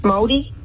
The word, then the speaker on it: Māori: Māori? – Oh.